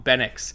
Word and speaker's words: Benix: Benix, because I kept thinking, is it Benoit, Benoui or something? I thought is this supposed to be a hard X, Benix? But in everything I've heard people talk pronounce his name, that's how they say it.